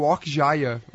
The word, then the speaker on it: Okja: O Okja.